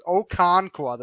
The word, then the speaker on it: Okonkwo: I got the name right this time. Well, I looked it up. I had to listen for it. It was Okonkwo, the tight end from Maryland, plus the Tagovailoa stack we talked about, Wall.